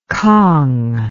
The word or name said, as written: kang